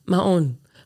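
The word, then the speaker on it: Mahón: Jahrhundert zurück, genauer gesagt auf die Balearen und die dortige Hafenstadt Mahon. Ich glaube nicht, dass ich Mahon richtig ausgesprochen habe, aber. – Mahon. Hat so ein Accente.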